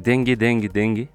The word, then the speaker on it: Dengue Dengue Dengue: Donc morceau qui s'appelle Oiseaux en featuring avec Dengue Dengue Dengue. Je sais pas si c'est comme ça qu'on prononce. Exactement. Comme ça, je crois. Comme la dingue, la maladie, et qui est donc un duo qui a créé ce label Quebrada.